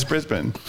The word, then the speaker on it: Brisbane: Like, if you look up Brisbane, as a Westerner, you'd read it as Brisbane. – What the hell? – Doesn't that read Brisbane? But it's Brisbane.